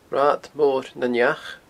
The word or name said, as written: Rathad Mor nan Each